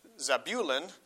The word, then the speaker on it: Zebulun: Actually, it's Zebulun, the emphasis on the middle syllable there,